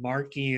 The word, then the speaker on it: Marc: And Marky is spelled with a K. And you have my name, Mark, M-A-R-C, and with the S, of course, and then world.